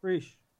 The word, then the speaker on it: Preisch: Am I saying that right? Is it Preisch or Preisch? – Preisch. – Okay, it's Preisch, but like his Twitter handle is Preisch is Right.